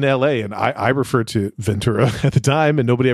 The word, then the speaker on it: Ventura: I think the correct, quote unquote, correct way to say it is Ventura. – Ventura with a C. – Not with a CH.